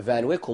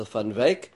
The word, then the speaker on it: Van Wyck: I was driving up the Van Wyck, or more correctly pronounced in Dutch as the Van Wyck,